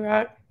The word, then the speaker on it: Rak: Good to see you, Rock. – Yeah. Hi, my name is Dr. Rock. It's uh, spelled R A K, pronounced R O C K.